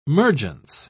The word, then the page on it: mergence: British English